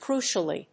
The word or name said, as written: crucially